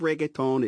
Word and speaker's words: reggaeton: We'll hear from Carlos Vives a little later, but right now, the queen of reggaeton is up next. – Yes, Bobby. Also, great pronunciation of reggaeton.